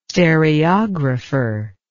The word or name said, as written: stereographer